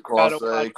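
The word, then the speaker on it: Clase: Yeah, and always hurts to see when Class A comes in, and isn't that how you say his name? – Yeah, Class A.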